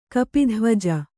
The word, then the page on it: ಕಪಿಧ್ವಜ: kapidhvaja